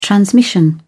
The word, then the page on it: transmission: British English